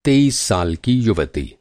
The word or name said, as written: युवती